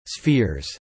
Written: spheres

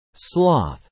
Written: sloth

